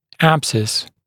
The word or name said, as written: abscess